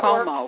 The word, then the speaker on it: Cuomo: Governor Cuomo, I never know how to say it. I'm going to call him Governor Andrew. I never can say his name right. – Works. – Huh? – That Cuomo.